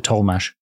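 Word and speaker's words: Tollemache: These days, I think the Tollmash family pronounce it Tollmash.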